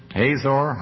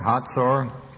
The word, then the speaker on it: Hazor: And the Lord sold them into the hand of Jabin, king of Canaan, that reigned in Hazor, Hatzor, whatever pronunciation you like, it's fine, same place.